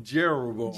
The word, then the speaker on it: Jeroboam: Rehoboam, and then it's how do you pronounce it? – Jeroboam. – Jeroboam.